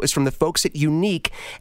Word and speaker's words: Yuneec: The company is called Yuneec. I love the way they spell it. Y-U-N-E-E-C.